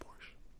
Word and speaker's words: Porsche: Isn't it pronounced Porsche? No? It's not? It's just Porsche? – Just Porsche.